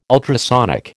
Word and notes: ultrasonic: American English

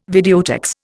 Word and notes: videotex: Indian

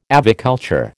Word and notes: aviculture: American English

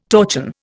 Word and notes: torchon: Indian

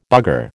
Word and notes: bugger: American English

